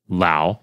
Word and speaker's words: Lou: But anyway, this particular review, yeah, a user by the name of their name is spelled like Lou, L-O-U, but then it says rhymes with wow. So I guess it's Lau.